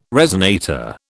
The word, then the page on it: resonator: British English